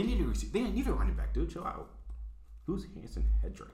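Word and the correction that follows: Haason Reddick: Hassan Reddick. – What did I say? – Not that. You no. pronounce it 100% wrong.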